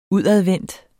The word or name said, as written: udadvendt